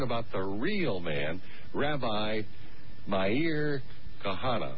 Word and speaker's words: Meir: Yeah, my pronounce, first of all, let's get her name pronounced correctly, Rabbi. And it's Meir. – Meir. – Meir? – Meir Kahana. – Meir. Meir Kahana. – Meir, like, daily.